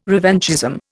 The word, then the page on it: revanchism: Indian